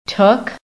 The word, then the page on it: TOOK: American English